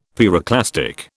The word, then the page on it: pyroclastic: British English